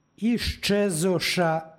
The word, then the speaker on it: iščezoša: psalma izgovorili smo iščezoša ispitajući i ispitanija, iako piše izčezoša, ovdje je došlo do jednačenja suglasnika.